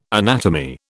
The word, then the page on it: anatomy: British English